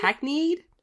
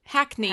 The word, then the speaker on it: hackneyed: Coda finds engaging characters and real emotions in a hack hackney hackney. I cannot read this. How do you say this word? What word? – Hackneyed. – Hackneyed. Hack, hackneyed. Yes um, emotions in a hackneyed, hackneyed narrative arc.